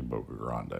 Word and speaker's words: Boca Grande: Brad always says the locals call it Boca Grande. Everyone else calls it Boca Grande. – It is actually Boca Grande.